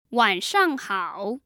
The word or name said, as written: wanshanghao